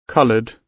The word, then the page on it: coloured: British English